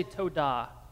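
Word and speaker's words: todah (תודה): Let me hear you all say todah.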